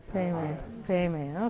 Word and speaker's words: Padme: The Tibetans say Payme.